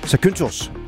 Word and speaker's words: Zakynthos: Vi sidder på terrassen på vores skønne hotel på Zakynthos. Jeg håber, det er udtalt rigtigt. Det er i hvert fald en græsk ø. Zakynthos er nok mere rigtigt.